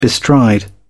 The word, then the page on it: bestride: British English